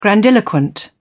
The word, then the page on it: grandiloquent: British English